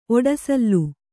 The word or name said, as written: ಒಡಸಲ್